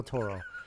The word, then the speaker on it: Toro: Guillermo del Toro. Yeah. – Anyways, she is a big, huge horror fan. And remember to roll the R. Toro.